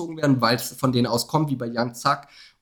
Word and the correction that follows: Young Thug: und äh, Sack, keine Ahnung, wie es ausgesprochen wird, Dicker. – Young Thug. Und ja, Bruder, du weißt doch, meine Aussprache, schlimm.